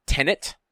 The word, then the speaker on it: Tenet: I keep saying it with a second N in it, and there's only one. – Oh, you said that backwards. Can you say the name again? – Tenet? – All right, you said it right that time.